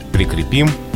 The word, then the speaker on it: прикрепим: Словари фиксируют только «прикрепишь», «прикрепим», прикрепят ударение только на окончании.